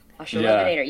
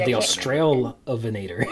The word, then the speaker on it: Australovenator: Australovenator. Yeah. Yes. The Australovenator.